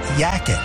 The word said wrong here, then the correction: yacht: After it came up several times, my friend asked to see the word. She laughed as much as was polite and told me it was yacht.